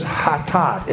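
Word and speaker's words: Hatat (חטאת): Okay, the biblical words in the basic word in the Old Testament is hatat, H-A-T-A-T. I think I left a blank there in your notes. H-A-T-A-T, and actually it's a short A followed by a long A. Hatat would be the best way to pronounce that.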